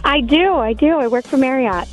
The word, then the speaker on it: Marriott: So the actual family last name, instead of pronouncing it Chariot, it's Marriott.